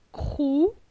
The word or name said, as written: Crou